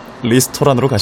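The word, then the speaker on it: 리스토란 (ресторан): 러시아 글자를 처음 본 사람들은 다들 팩토파라고 읽죠. 아. 일본에는 실제로 팩토파 모임이란 게 있다고 해요. 러시아 여행이 어려웠을 때 왔었던 사람들의 모임이라나요. 저 단어는 러시아어로 레스토랑입니다. 아. 팩토파 아니고 리스토란으로 가실까요?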